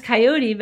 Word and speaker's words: Kaiyote: So my name is Kaiyote, but I spell it K-A-I-Y-O-T-E.